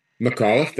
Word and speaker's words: McAuliffe: Is it McAuliffe? – McAuliffe, that's it.